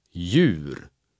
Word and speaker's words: Djur: In den Verbindungen DJ, GJ, HJ, LJ bleibt der erste Konsonant stumm und die ganze Gruppe wird ebenfalls wie J ausgesprochen. Jag, Djur, Juta, Jul, Ljus.